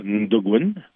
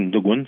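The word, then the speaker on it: Natukun: tout ça pour aussi pour mon album, en fait, qui se prononce Ndogun. Ça s'écrit Natogun, mais on dit Ndogun.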